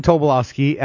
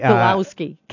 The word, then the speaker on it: Tobolowsky: I keep saying it wrong. – It's Stephen Tobolowsky. – Tobolowsky.